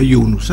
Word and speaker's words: Yunus: El amigo Mohamed Yunus, se pronuncia Yunus, ¿sabe usted? En el barrio le dicen, ¿qué hace Yunus? No dicen Yunus, como decimos acá.